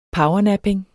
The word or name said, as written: powernapping